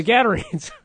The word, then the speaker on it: Gadarenes: The Gadarenes.